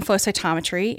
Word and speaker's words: flow cytometry: And I was supposed to be doing flow cytometry, and I fell into this microscope. – Wait, you keep throwing syllables here. Photocytometry. – Flow cytometry.